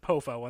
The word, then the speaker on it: Poffo: I always pronounced it Poffo, and that's what I've always heard, but, yeah.